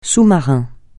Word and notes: sous-marin: francais

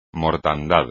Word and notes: mortandad: espagnol

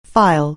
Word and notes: file: anglais

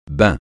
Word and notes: bain: francais

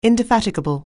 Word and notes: indefatigable: anglais

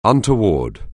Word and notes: untoward: anglais

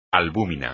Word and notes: albúmina: espagnol

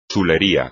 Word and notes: chulería: espagnol

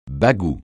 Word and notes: bagou: francais

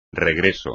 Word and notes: regreso: espagnol

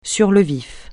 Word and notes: sur le vif: French

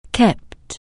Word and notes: keep: anglais

